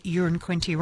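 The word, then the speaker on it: Uranquinty: And I love this because Peter's texted in saying, I live in Urin Quinty. It's the same pronunciation as a number one, so it's not a big town. One could describe it as a wee place.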